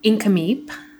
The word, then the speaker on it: Nk'Mip: So it's how it's spelled is different than how it's stated. Mm. So it's Incamip is how you pronounce it. And it's NK'MIP.